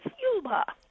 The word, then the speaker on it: Yuleba: When I first came out here, I thought Y-U-L-E-B-A was Yuleba. And I found out it's Yulba. Depends on where you put the pronunciation.